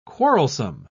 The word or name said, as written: quarrelsome